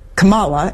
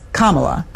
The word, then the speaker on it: Kamala: You said Kamala, and it's Kamala.